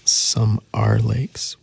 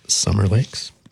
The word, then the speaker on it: Some Are Lakes: So continuing on, sort of with the summariness, I'm going to play a song by Land of Talk from the album Some Are Lakes, which is really hard not to pronounce Summer Lakes.